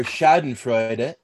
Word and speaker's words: Schadenfreude: Schadenfreude, or Schadenfreude, if you want to go to the German pronunciation, is pleasure derived by pleasure derived by someone from another person's misfortune.